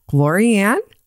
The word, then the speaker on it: Lori-Ann: Lori Ann? Lorraine? And I would say, no, Lori Ann, like L-A, Lori Ann.